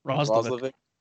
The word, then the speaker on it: Roslovic: And I was reading something earlier about the Roslovic. Am I saying it right? – Roslovic. – Yep.